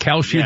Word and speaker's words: Kalshi: Kalshi, yeah, the gambling Kalshi app. And now it turns out that four Native American Indian tribes are breaking with the casino establishment for their gambling and going with Kalshi.